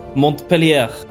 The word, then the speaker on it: Montpellier: Montpellier. Är du nöjd nu? – Jag tror inte du uttalar ellerna faktiskt.